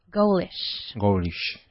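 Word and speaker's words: ghoulish: But look, listen to the pronunciation. It's not like goal de gol de penalti or something similar to this. It's G-H-O-U-L-I-S-H. Goalish.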